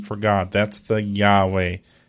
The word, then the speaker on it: Yahweh: And in the Hebrew, that is pronounced Yahweh. Yahweh is the name of God as revealed to Moses in the burning bush.